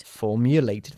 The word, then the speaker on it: formulated: Formulated three questions. ¿Por qué pronunciamos la E final de formulated? – Porque formulate acaba en sonido de T.